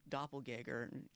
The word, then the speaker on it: doppelganger: You're trying to find her doppelganger.